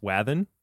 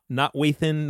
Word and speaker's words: Wathan: I will say to some of y'all that his name is pronounced Wathan, right? That's right, right? – Yeah, Wathan. You got it. – Not Wathan, not Wathan. I'm trying to think about the other things I've heard, but Adam Wathan.